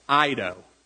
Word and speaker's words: eido: Because I love this word in the original Greek is E-I-D-O, which is pronounced like Ido, okay?